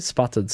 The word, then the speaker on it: sputtered: Unusually, the T2 features a handcrafted titanium spluttered. Is that how you say it? Sputtered, sorry. Capsule, not spluttered.